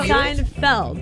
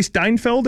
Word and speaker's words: Seinfeld: Like no, Seinfeld. What did I say? Seinfeld. – I was like, wait, it may be spelled like that. – Friends. I'll go Friends. – You don't want to say Steinfeld or whatever you said earlier? – Steinfeld.